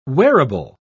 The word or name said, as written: wearable